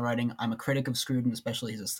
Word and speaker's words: Scruton: But It's just Scruton. – Scruton. Yeah. So it's not Scruton, like Crouton. Okay. – To my knowledge, no.